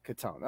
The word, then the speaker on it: Cottone: How do you say the last name? Cottone or Cottone? – Cottone. – Okay, Cottone. Okay.